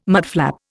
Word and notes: mudflap: Indian

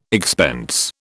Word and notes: expense: British English

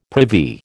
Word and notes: privy: American English

